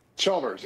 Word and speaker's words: Chalmers: Chalmers.